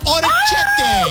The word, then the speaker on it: orecchiette: Orecchiette. – Orecchiette.